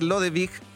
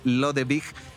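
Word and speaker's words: Lodewijk: pero sí, la pronunciación, tal cual como tú lo decías, es Lodewig.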